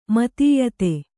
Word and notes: ಮುತ್ತಯಿದೆ: muttayide

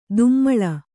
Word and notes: ದುಮ್ಮಳ: dummaḷa

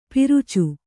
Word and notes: ಪರಚು: kannada

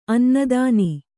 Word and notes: ಅನ್ನದಾನ: kannada

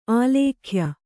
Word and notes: ಆಲೇಖ್ಯ: kannada

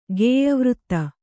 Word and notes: ಗೇಯವೃತ್ತ: kannada

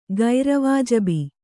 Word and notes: ಗೈರವಾಜಬಿ: gaira vājabi

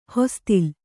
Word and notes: ಹೊಸತಿಲ್: Kannada

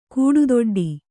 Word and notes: ಗಟ್ಟಿದುಡ್ಡು: gaṭṭiduḍḍu

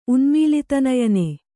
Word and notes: ಉನ್ಮೀಲಿತನಯನೆ: kannada

